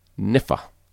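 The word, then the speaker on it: Kniphfer: Also, if you like to mention my name, it is Taylor Niffer. You do pronounce the K, so it's K-N-I-P-H-F-E-R, but you only pronounce one of the F sounds.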